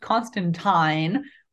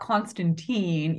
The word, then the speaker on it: Constantine: Oh, another thing is that his name is pronounced Constantine, not Constantine, even though Constantine is the common pronunciation.